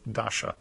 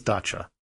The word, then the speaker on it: dacha: And thanks especially this week to listener James K., who informed me that last episode I mispronounced the word dacha, and I said it as dasha, but it is dacha.